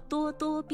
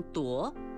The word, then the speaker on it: 咄咄: 多多也是叹词，表示感叹或惊诧，形容气势汹汹、盛气凌人，叫多多逼人。使人非常惊讶的是叫多多怪事。多只有一种读音，请不要读成夺，说咄咄逼人、咄咄怪事是不正确的。